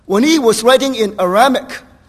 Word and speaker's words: Aramaic: One is in Hebrew, the other is in how do you pronounce it? Aramaic. Thank you. Aramaic.